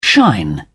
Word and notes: shine: British English

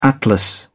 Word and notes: atlas: British English

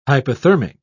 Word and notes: hypothermic: British English

